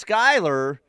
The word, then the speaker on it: Schuyler: Shiler, pronounced Skyler, would like the Commanders. Shiler is spelled S-C-H-U-Y-L-E-R.